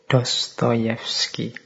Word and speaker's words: Dostoyevsky: Jadi Dostoyevsky ini, ini mengucapkannya harus agak pelan-pelan nanti kalau kepleset ya. Fyodor Mikhailovich Dostoyevsky. – Ini membacanya kurang lebih seperti itu. Kalau tulisannya kadang-kadang ada yang mengejanya Dostoevsky, tapi membacanya biasanya ya seperti tadi, Dostoevsky.